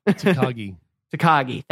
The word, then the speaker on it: Takagi: Takashi. Did I get that right? Hopefully I got that right. I don't know. – Takagi. – Thank you. – Takagi, Mr. Takagi.